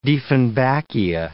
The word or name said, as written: dieffenbachia